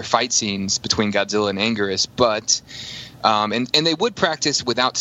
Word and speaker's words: Anguirus: Because of that, he can also fight another monster, which is Anguirus, or Anguilus. I still go with that old-school American Anguilus pronunciation, where he was played again by Tezuka.